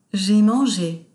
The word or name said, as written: Jai mange